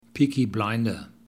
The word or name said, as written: peaky blinder